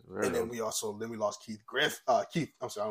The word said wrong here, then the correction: Giffen: Keith Giffen. When you see his name, you want to say Griffin for whatever reason, but it's Keith Giffen.